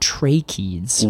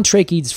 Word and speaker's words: tracheids: I'll define tracheids first. – Okay. – Tracheids, and that is spelled T-R-A-C-H-E-I-D-S. – Yes. – Tracheids. – Like trachea. – Exactly like trachea.